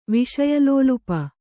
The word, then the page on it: ವಿಷಯಲೋಲುಪ: Kannada